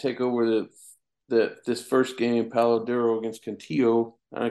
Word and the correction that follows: Canutillo: Canutillo. You got to guess Canutillo. – that Y for the L, the double L's.